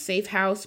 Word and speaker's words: Safe HQUSE: It's pronounced Safe House, but it's like headquarters for the HQ.